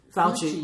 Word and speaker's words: Fauci: I want to say Fauci.